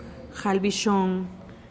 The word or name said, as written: jalbixoon